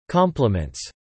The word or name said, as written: complements